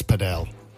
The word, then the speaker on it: padel: It's padel. – What? – Many people pronounce it padel.